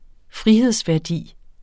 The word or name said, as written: frihedsværdi